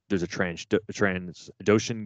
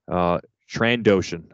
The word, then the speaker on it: Trandoshan: Is it Transdotion or Trandoshan? How's it pronounced? Cause I feel like I was listening to you guys talk about it. And then there were some different pronunciations of this. – We're probably all over it. Let me look up Bosk and see what they call him. Trandoshan. I'm sorry. No S after Tran. So Tran.